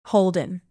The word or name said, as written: holden